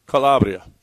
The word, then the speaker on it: Calabria: Calabria.